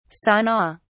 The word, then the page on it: sana: British English